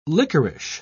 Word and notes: lickerish: British English

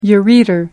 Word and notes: ureter: American English

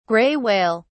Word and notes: gray whale: British English

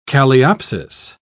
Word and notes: calliopsis: British English

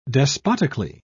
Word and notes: despotically: British English

